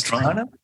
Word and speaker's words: Toronto: Toronto. We can't say that? – No, it's Toronto. – All one term. – Toronto. – Just like Toronto. – Not Toronto.